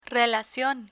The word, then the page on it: relacion: spanish